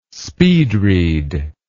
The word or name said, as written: speed read